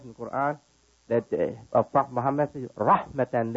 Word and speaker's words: Quran: In our holy book, the Quran, it's called Quran. By most Westerners, they pronounce it Quran. Quran, K-O-R-A-N, Quran. Pronounced correctly by Muslims, Quran.